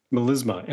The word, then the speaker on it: melisma: Melisma. M-E-L-I-S-M-A.